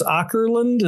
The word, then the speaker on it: Åkerlund: Jonas Åkerlund? Is that how you say it, Tim? – Yes, that's right.